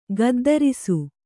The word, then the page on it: ಗುದ್ದರಿಸು: guddarisu